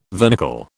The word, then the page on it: vernicle: British English